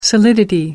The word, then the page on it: solidity: American English